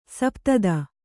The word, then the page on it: ಸಪ್ತದ: Kannada